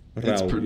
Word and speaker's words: Rauw: Rauw, yeah, because his actual name is Raul, like with an L, but then yeah, it's just like, I don't know, stylized that way.